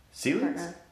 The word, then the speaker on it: Sealy: Sealy's? – Yeah. How do you say their last name? – Sealy. – Oh, there you go. I thought it was Steel. – There's no T.